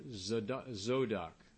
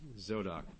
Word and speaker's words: Zadok: Just the emphasis is on the wrong syllable, so we don't notice that it's Zodok or Zadok, as the Jews would say it.